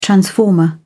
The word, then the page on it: transformer: British English